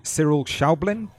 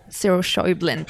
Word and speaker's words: Cyril Schäublin: So this is the film called Unrest by Cyril Schäublin. – Cyril Schäublin, please. – Schäublin. Okay, I always get my pronunciations wrong, so apologies to Cyril.